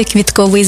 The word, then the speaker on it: квітковий: Я прямо відчуваю цей квітковий запах. До речі, правильний наголос саме квітковий, бо стосується квітів. А квітковий – це про органи розмноження за допомогою квіток.